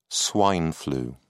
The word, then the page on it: swine flu: British English